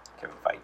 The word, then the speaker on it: Kevin Feige: And if we want to get into, it's pronounced Feige? – Kevin Feige. – I thought it was pronounced not that way this whole time.